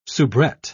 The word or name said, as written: soubrette